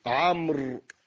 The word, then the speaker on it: عمرو: كذلك كلمة عمرو، هذه الواو تكتب ولا تنطق حتى يفرق بينها وبين عمر،